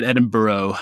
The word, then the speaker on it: Edinburgh: Yeah, I went to University of Edinburgh. – I didn't say it right. I said Edinburgh.